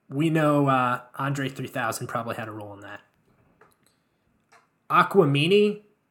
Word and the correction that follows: Aquemini: am I pronouncing that correct? – No, Aquemini.